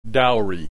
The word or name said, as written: dowry